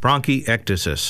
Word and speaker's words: bronchiectasis: Let me hear you say it correct. – Bronchiectasis. – There you go.